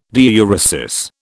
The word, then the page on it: diuresis: British English